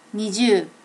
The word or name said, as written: niju